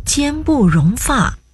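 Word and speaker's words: 间不容发: 一样啊，正确读音是奸不容发。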